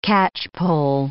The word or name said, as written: catchpole